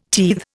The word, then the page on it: teethe: Indian